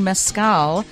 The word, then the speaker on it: mezcal: And we are talking mezcal.